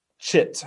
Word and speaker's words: chit: I have to be sure to enunciate here because it's important. Chit. – Yeah, say it correctly. – C-H-I-T.